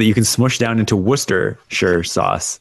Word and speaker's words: Worcestershire: The most glaring example of this to me is always going to be Worcestershire sauce. There's so many syllables or so many letters smushed into Ooster.